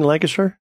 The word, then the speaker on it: Lancashire: I'm thinking Lancashire. – Lancashire? Sure. Just like jam it all together really quickly.